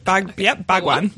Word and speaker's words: Bhagwan: Nope, that was perfect. That was perfect. Bhagwan. This is one I actually know how to pronounce for once. – Well, I kept hearing it, and I'm like, that doesn't sound right. – Yeah. – Yeah, it's Bhagwan.